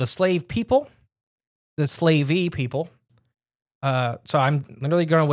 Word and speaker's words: Slavey: Let's talk about the slavey. I think that's how it's pronounced. It's S-L-A-V-E, which is slave, right? – Yeah. – Why? – I would say slavey.